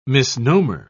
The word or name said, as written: misnomer